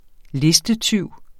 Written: listetyv

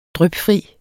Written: drypfri

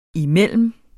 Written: imellem

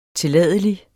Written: tilladelig

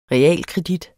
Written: REALKREDIT

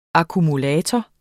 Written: akkumulator

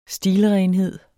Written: stilrenhed

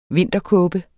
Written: vinterkåbe

